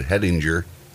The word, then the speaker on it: Hettinger: She says it Hettinger. She changes her dad's pronunciation of their last name to Hettinger, not Hettinger. She says it sounds like they're going Hettinger when they say her name in sporting events.